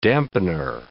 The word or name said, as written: dampener